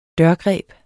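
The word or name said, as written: DØRGREB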